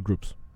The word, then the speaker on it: Grups: Is it pronounced Grups or Groups? – Grups. – Grups.